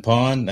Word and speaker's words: pwned: I've been pawned. I think, Have I Been Pawned? That it doesn't, you know, it's spelled weird, PWNED,